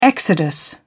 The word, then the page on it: exodus: British English